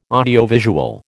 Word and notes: audiovisual: American English

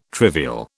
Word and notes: trivial: British English

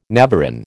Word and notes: navarin: American English

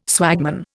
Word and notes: swagman: Indian

